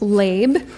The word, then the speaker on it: lev: It looks like lev, but it's pronounced lab.